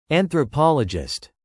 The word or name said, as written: anthropologist